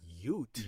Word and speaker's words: hjort: Yeah, it's actually spelled H-J-O-R-T. Ute.